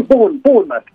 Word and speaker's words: Bournemouth: Sì, Bournemouth, dove è quasi finito Zaniolo. – Sì, Zaniolo, sì, era perché siccome non sapevamo bene come pronunciarlo, come si dice Bournemouth, giusto? – Sì, Bournemouth, è una bella città, è tipo 40 km da Southampton.